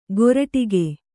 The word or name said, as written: ಕೊರಟಿಗೆ